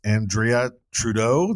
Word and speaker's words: Andrea Trudeau: This is Miss Andrea Trudeau.